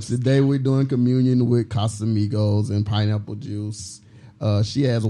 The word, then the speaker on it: Casamigos: Shout out to Casamigos. That's how you say it.